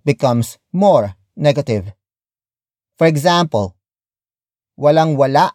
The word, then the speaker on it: walang wala: Walang wala, akong, pagkain.